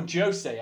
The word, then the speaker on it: José: It's called The Double by Portuguese author, she's educated, José Saramago, or José, actually, they say in Portugal, not José. José is Spanish, would be Spanish. José, you pronounce the J in Portuguese.